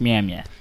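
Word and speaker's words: Mjölnir: It's actually pronounced Mjolnir.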